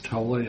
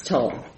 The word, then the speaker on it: Tole: Ms. Tolle, am I butchering your name again? – It's Toll. T-O-L-E, Toll. It's a lot of vowels.